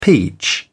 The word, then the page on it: peach: British English